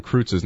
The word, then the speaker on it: Kreutz: It's like one time I heard I heard uh, Chris Berman mispronounce Olin Kreutz's name, like he said Olin Krutz or something like that.